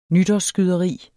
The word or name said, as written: nytårsskyderi